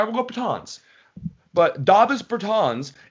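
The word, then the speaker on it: Dāvis Bertāns: I believe it is Davis Bertans. Yes. – Davis not Bertans. Davis. I said it as white as possible. It's Davis Bertans.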